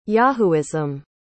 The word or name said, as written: yahooism